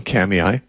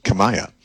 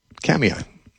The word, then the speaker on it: Kamiah: Now, if you're in central Idaho, I'm going to be speaking in a small town, Kamiai, in the Clearwater Valley, which if you look up on a map, it doesn't look like it should be pronounced Kamiai. It looks like it should be pronounced Kamiah. It's K-A-M-I-A-H. I guez it's a Nez Perce Indian word, and they pronounce it Kamiai.